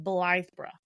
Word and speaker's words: Blythburgh: I looked up the pronunciation and then I wrote it out phonetically on my thing. Bungay and Blythburgh.